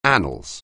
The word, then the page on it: annals: anglais